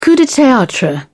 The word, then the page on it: coup de theatre: British English